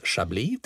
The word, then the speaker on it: Chablis: Så att det vi lär oss nu då när vi pratar Chablis, det är att det uttalas Chablis till att börja med.